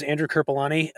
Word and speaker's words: Andrew Kerpulani: So my name is Andrew Kerpulani. You got the pronunciation right. It's perfect, spelled exactly like it sounds, but I think a lot of people just see a name over six letters and kind of give up.